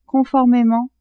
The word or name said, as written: conformement